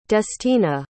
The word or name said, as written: destina